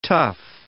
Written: tuff

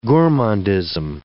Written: gourmandism

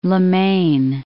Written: Le Maine

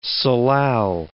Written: salal

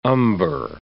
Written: umber

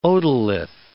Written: otolith

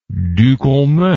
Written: Ducommun

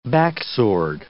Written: backsword

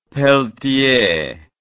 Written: Pelletier

